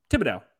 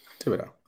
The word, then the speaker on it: Thibodeau: I guess really the most important thing is someone on the opposite of Thibodeau moving into next year. – Who? – Thibodeau? – Yeah, you got it. – Thibodeau? – No, there's no TH. It's just Thibodeau. Thibodeau. – Thibodeau.